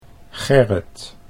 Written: Gerrit

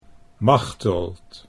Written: Machteld

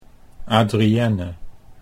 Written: Adrienne

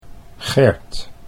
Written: Gert

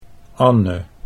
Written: Anne

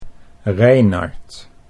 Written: Reinard

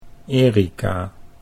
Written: Erica

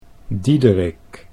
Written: Diederik

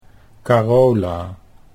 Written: Carola

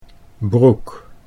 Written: broek

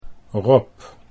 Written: Rob

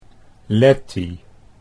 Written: Lettie

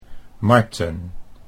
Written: Marten

